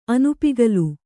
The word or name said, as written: ಅನುಪಿಗಲು